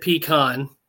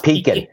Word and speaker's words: pecan: We pronounce it pecan because not pecan.